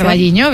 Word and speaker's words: O Carballiño: O Carballiño, no lo sé pronunciar. – O Carballiño, bien, exacto.